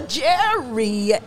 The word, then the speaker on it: Gerianne: Oh, Gerianne.